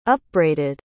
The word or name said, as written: upbraided